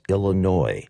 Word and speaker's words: Illinois: straight out of Chicago, Illinois. The Three Degrees from 1974, and for those of you who don't know how to pronounce it, the S is silent. It's Illinois, not Illinois, all right?